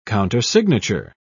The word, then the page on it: countersignature: British English